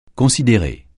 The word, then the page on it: considérer: francais